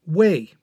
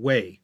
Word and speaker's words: Wei: People who have studied Chinese history from this time recognize this Wei state as the one that's spelled W-E-Y in order to differentiate it from the other big Wei of the day, which was a different character but shared the same fourth tone in its pronunciation. Two Weis, same pronunciation, different Chinese character, and different romanization. The other Wei, W-E-I, was located in northeast Henan, spilling over the border into southern Hebei.